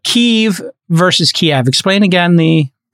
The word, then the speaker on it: Kiev: But the spelling, the transliteration in English that the Ukrainians use, which has now become like a culture war thing, is K-Y-I-V. And in my best Ukrainian accent, which is total garbage, it is kind of a longer I. It's not, but it doesn't turn into an E. It's Kiev. – Kiev.